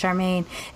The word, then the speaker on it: Sharmane: If I'm in a job interview or like I have a white boss and they're just like, I'm just gonna call you Char, and I'm like, no, you're not, because that's not my name. My name's Charmaine. It's not hard. It's two syllables.